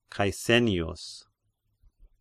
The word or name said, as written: Caesennius